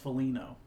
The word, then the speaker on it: Foligno: You're pronouncing the G. – Was I really? – You said Foligno first, and then you said Foligno. – Oh my gosh. I'm losing it. – It's just Foligno.